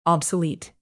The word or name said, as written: obsolete